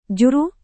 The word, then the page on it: juru: Indonesian